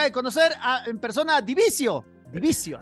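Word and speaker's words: Dvicio: La banda española De Vicio, que se pronuncia Divicio, regresa a México a dar dos excelentes shows en la Ciudad de México.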